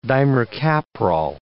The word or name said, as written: dimercaprol